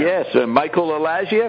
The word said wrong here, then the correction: Alig: I think it's... Just Alig, I think. – Yeah, A-L-I-G. – Not Elijah.